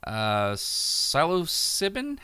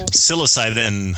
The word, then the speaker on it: psilocybin: gave 12 people psilocybin? How do you say that, people? – Psilocybin.